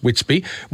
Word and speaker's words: Witsby: Now, Witsby, W-I-T-S-B-B, that's how it's spelt. You'll see that on the products. It's pronounced Witsby.